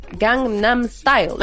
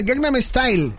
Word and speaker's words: Gangnam Style: ¿Por qué me cuesta trabajo decir Gangnam Style. – ya lo dijiste, lo dijiste muy bien. – No tuviste ningún problema. Gangnam Style.